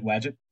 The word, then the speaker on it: Wadjet: So is that how you pronounce it, Wadjet? – Well, isn't it Wadjet? Wadjet Eye? – Yeah. There you go.